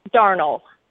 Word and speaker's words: Darnall: And then is it Darnall Army Community Hospital or Darnall? How is that pronounced? – Darnall. – Darnall. Okay. – All right. Like darn it.